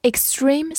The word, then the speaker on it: extreme: e x t r e m e，extreme，e x 发 x，t r 因为前面有 s 这个音浊化成 z，然后中间的字母 e 发长音 e，末尾的 e 不发音。 extreme，extreme，它是一个形容词，表示极端的、极度的。好，比如说 extreme sport。